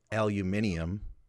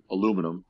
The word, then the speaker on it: aluminium: One of them is what he calls aluminium. That's how he pronounces it. Uh, aluminum.